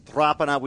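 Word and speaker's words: Rapana: His mum was into us for years saying it's Rapiner.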